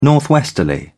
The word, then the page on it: northwesterly: British English